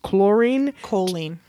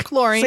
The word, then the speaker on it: choline: So when you eat eggs, your brain uses choline. – Oh, I'm sorry. – Or chlorine.